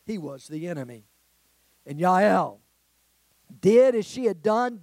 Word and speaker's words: Jael: Or Yael is pronounced in Hebrew.